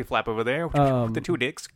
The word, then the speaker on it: Tudyk: And I did say Tudyk because that's his name. – That's how you say it.